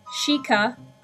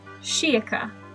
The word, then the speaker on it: Sheikah: I've heard it pronounced, well, the same as his name, obviously, because they're very, very similar, but I've heard Sheikah, and I've also heard Sheikah. Now, personally, I like the pronunciation with the I, but I know that that's not the most widely accepted version of that word.